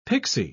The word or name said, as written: pixie